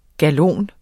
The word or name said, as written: GALONERNES